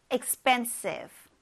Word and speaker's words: expensive: expensive, expensive, expensive. 네, 여기서 이 expensive 할 때 이 p가 들어가 있어서 많은 분들이 expensive 이렇게 발음을 하는데 좀 이렇게 expensive, expensive 이렇게 된 소리에 가깝다는 거 유의하세요.